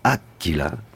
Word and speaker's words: Aquila: Cette région de l'Aquila, Aquila, comme vous m'avez repris au préalable de l'émission sur l'accent tonique que j'avais mal placé en étant en Italie et pas en Espagne.